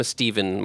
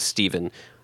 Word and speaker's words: Stephen: I'm a Stephen. My full name is Stephen. Go by Steve, but I'm a PH Stephen.